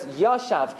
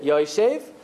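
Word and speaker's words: Yoshev (ישב): So Rashi says, Yashav Ksiv. It's written that he sat. But we read it, Yoshev, says Rashi, Bikesh Lamad.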